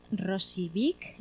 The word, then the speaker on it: Rosillo-vich: Para ello pues vamos a empezar hablando con Gloria Ramos y Robert Rosijovic, ¿lo he dicho bien? – Rosijovic.